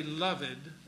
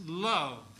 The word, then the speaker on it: loved: Note that the third word is pronounced in one syllable. And notice they put the apostrophe before taking out the E there. So that's loved. Don't say loved, it's loved. The apostrophe indicates to merge this into a single syllable.